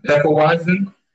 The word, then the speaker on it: Hefeweizen: I'm going to have, and I'm not going to pronounce this right, the uh, the Hefe, Hefeweizen.